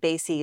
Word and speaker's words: Basi: K-A-T-H-L-E-E-N. And Basi is B-A-S-I. I'm married in Italian. – Married in Italian. That's what happens. You get a nice, efficient last name that way. B-A-S-I. – Right. – Nice and easy to spell.